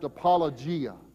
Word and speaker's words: apologia: The word defense is the translation of a good Greek word that is pronounced apologia, from which we get our English word apology, and that's not the idea of Peter's word here.